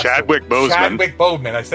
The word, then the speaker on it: Chadwick Boseman: So, good God, I just butchered his name here. Bowman Chapman had not passed. – Chadwick Boseman. – I know I messed it up.